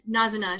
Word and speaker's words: Nazuna: is that Nazuna? It was her name? – Yeah, that sounds about right. – Yeah, Nazuna.